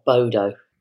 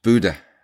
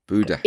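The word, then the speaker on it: Bodø: Listen, I'm no expert, and I'm sure I'm saying it wrong, but it's not Bodo. – It's Buda. Buda.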